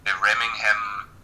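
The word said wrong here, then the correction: Remenham: Cup. I don't know how to pronounce that. I think it's Remnham. Remnham Challenge Cup, women's eight.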